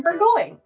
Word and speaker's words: Burgoyne: In the first of a series on found family and queer romance, I have with me author Nathan Burgoyne. Welcome, Nathan. – Hello. Thank you for having me. – Did I actually say your last name right? – You did. Yes, that's perfect. Rhymes with sirloin.